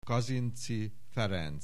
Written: Kazinczy Ferenc

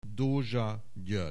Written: Dozsa Gyoergy

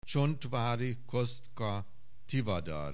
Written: Csontvary Kosztka Tivadar

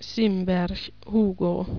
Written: Simberg Hugo

